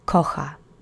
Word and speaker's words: kocha: The first word is kocha. It means he, she, it loves. Third person singular. The accent is on the first syllable.